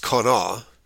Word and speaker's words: qaneh: The KJV translates the Hebrew word for kane, Q-A-N-E-H, pronounced kana, into calamus.